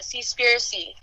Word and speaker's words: Seaspiracy: Yeah, Seaspiracy.